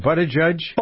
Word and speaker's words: Buttigieg: Buttigieg, how do you pronounce his name? – Buttigieg.